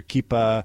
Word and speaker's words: kippah: You had your prayer shawl, you had your kippah. Did I pronounce that correctly? – You did. You did.